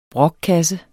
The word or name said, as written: brokkasse